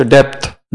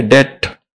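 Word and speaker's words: debt: वैसे ही तुम्हारे सिर पर जन्म जन्म का कर्जा है, डी ई बी टी। लोग पढ़ते इसे डेप्थ, डेप्थ नहीं है, डेट, बी साइलेंट है।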